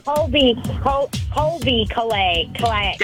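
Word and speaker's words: Colbie Caillat: Callie Kalei, I think how you say your name. – Not quite. – Colby Kalei. – That's right.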